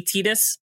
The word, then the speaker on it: Tidus: Also, and to be fair, to be fair, I in Japanese is pronounced E, so it would be Tidus if you're reading the Japanese translation of it.